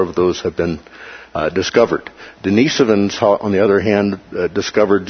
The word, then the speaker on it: Denisovans: Denisovans, okay? So that's how you pronounce that. Like Denise Evans, right? Denisovans.